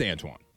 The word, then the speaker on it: Antoine: I struggle with this because my name is Antoine. Mm-hmm. My name is spelled A-N-T-O-I-N-E.